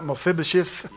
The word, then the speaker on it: Mephibosheth: Mephibosheth? – You said it right.